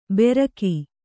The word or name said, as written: ಬೆರಕಿ